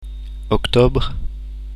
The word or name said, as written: octobre